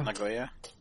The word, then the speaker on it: Nagoya: Nagoya? – Nagoya, yeah.